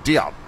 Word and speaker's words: Diop: They just I think the it's like it does have a almost like a chop, like Diop.